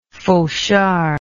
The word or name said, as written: fauchard